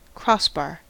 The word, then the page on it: crossbar: American English